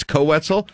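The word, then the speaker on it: Koe Wetzel: With apparently, and I think I mispronounced his name when we did the actual announcement at 8 a.m. I called it Coe Wetzel. It's Coe Wetzel.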